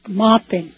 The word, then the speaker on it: Maupin: We're going to go from Maupin, Oregon. Did I say that right? – Yeah, Maupin.